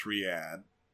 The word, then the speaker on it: Riyadh: And, oh lord, and just in case anyone was annoyed, yes, we know, it's pronounced Riyadh.